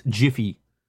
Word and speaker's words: GIF: And yes, it's pronounced Jiffy.